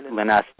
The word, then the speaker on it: Linus: Yes, I do know who Linus Torvalds is. Or Linus Torvalds. There we go. See, I pronounced Linus. – It right eventually.